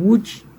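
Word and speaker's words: Łódź: Não, ano que vem, em abril, vai ser o 4º concerto de Villa-Lobos, com orquestra em Wood, se escreve Lodge, que é onde o Arthur Rubinstein nasceu.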